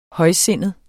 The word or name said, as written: højsindet